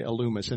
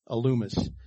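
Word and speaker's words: Elymas: So Sergius Paulus wasn't satisfied with what he was getting from this guy, Elumus. And it is pronounced that way, even though I want to say something different, but I looked it up. So I don't know. Elumus.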